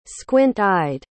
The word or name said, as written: squint-eyed